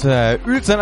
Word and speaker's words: Uelzen: Und zwar wird es Uelzen ausgesprochen. UE Uelzen. Wunderbar, vielen Dank, liebe Telegram-Gruppe.